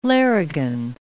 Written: larrigan

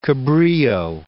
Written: Cabrilho

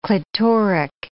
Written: clitoric

